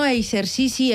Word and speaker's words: exercici: Jo crec que lligat a l'educació hi ha tres paraules com a clau que s'han de pronunciar igual, que seria la d'exercici, examen i exemple. – Uf, sí. – Que les tres van ahí lligadetes sí. I que s'han de pronunciar com una geseta, no?